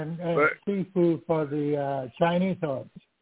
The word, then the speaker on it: sifu: The kung fu had sifu, or I think it's called, it's spelled S-I-F-U, but they want to say the, kind of like say the X, sifu.